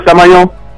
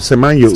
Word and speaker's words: Semenyo: How do you pronounce the name of the Ghanaian player who plays for Manchester City? – Manchester City? – Yes. – Oh, Semenyo. – Oh, okay. Okay. So, yeah, that's the way I heard. – We call him Semenyo.